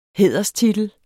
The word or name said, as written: hæderstitel